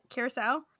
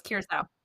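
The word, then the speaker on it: Curaçao: Am I saying that right? Curacao? Oh, yeah. It's curacao.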